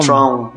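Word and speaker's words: Thrawn: É, a trilogia Tron, Tron, Tron, como é que fala isso? Tron. Tron, exatamente. É o Herdeiro do Império, a Sessão da Força Sombria e o Último Comando, os três livros, né?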